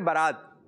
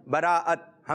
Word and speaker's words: براءت: کچھ لوگ کہتے ہیں برات، برات، برات نہیں، براءت، حمزہ بھی ہے، تا سے پہلے کیا ہے؟ حمزہ۔ کر سب صحیح تلفظ کیا ہوگا؟ – شب براءت، شب براءت کیا ہوگا جی؟